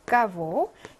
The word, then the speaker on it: кого: 생격부터 한번 볼게요. 까보. 자, 격변화 속에서 등장하는 개는 배발음 나니까 이렇게 읽고요.